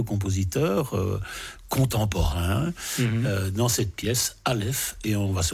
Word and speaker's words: Aleph: Ça s'appelle Aleph. A-L-E-P-H, c'est bien ainsi qu'on le prononce, cher Alain ? – Oui. – Oui, c'est bien ça.